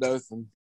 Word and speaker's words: Dothan: Is that, am I saying it right? Dothan? – I was at Dothan.